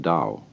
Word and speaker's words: Dao: You know that's spelled T-A-O. I don't know why, because it's usually pronounced Dao.